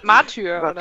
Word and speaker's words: Martyr: Ich weiß nicht, wie es ausgesprochen wird. Matter. – Martyr. – Martyr. – Martyr, ach so.